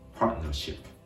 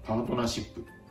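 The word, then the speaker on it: partnership: ここは、パートの t の音がやや欠落した形で、パートナーシップ。パートナーシップ。と発音されるところがポイントです。では、このパートナーシップフランス語で見ていきましょう。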